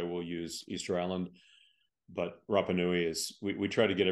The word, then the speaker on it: Rapa Nui: Am I saying it correctly? – Yes, Rapa Nui. Okay. It's the modern traditional name of the island. – Okay. – And so people on the island prefer Rapa Nui over Easter Island, but which makes sense.